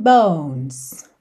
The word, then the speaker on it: bones: Oh, yeah, your skin and bones. Bones. Não pronuncie a letra E, tá bem?